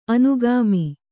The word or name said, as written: ಅನುಗಾಮಿ